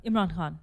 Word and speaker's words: Imran Khan: Amir Khan, or he used to be a cricket superstar. He ran for prime minister of Pakistan. – Imran Khan.